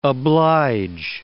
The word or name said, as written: oblige